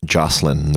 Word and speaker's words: Josselin: Surname goes with Josselin. The reason being, it's originally a French name, which is Josselin. – Okay. – So, but people go with Josselin every now and then.